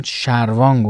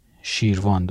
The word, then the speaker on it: شیروان: که من شروان گفتم این به خاطر اشتباهی بود که من کردم در زمینه مطابقت دادن تلفظ ها که متاسفانه از اولین منبعی که به دست آورده بودم استفاده کردم ولی بعدا رفتم تلفظ دقیقش رو حتی در زبان آذربایجانی چک کردم و دیدم که شیروان درسته از